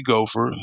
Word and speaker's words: Gopher: On LinkedIn, I'm Cody Gopher, CODIEGOPHER.